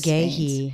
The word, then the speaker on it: McGaughey: There's a lot of Irish running through these Magehi. – Veins. Sharon K. Magehi. – How do you spell it? – M-C-G-A-U-G-H-E-Y.